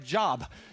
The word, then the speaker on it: Job: If you're looking it up on an app, it's spelled J-O-B like Job. But it's not Job, it's Job.